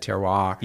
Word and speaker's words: terroir: So talking about that terroir, if I can pronounce it correctly.